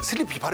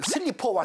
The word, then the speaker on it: 슬리피: 물론 이제 어르신들이 그 슬리피 발음이 좀 어려우니까 잘 안 돼가지고 네. 슬리퍼 왔어? 네. 슬리퍼 왔다? 뭐 이런 네.